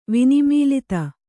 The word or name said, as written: ವಿನಿಮೀಲಿತ